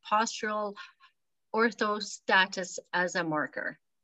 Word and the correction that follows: orthostasis: Yep, postural orthostasis, yes.